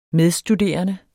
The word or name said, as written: medstuderende